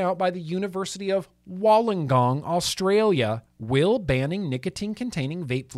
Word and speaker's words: Wollongong: Wollongong. Wollongong, Australia. Do you need to say it with an Australian accent? Wollongong. Wollongong. Oh, kinda.